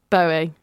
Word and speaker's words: Bowie: I've always said Bowie. Say it wrong? – No, you are saying it right. It's me. I'm wrong. – Oh, okay. – I'm wrong. It's David Bowie.